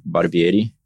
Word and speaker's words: Barbieri: B-A-R-B-I-E-R-I. Italian last name is not that easy to pronounce. It was actually years ago, like I never knew it was Barbieri or Barbieri. Mm. I had to do some research and understand what would be the right way of saying my last name.